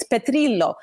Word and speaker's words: Petrillo: If we try to pronounce every single name the way it's supposed to be, for example, my last name, if you were to truly give it the Italian pronunciation, it's Petrillo.